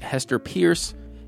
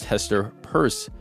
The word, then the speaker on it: Hester Peirce: I was saying Hester Peirce. It is Hester, pronounced Hester Peirce.